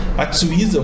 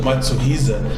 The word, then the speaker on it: Matsuhisa: Matsuiza ou Matsuhisa, depende de onde você pronuncie, mas ele é o ele é o cabeça da história, ele é o, ele é o japonês que comanda esse império.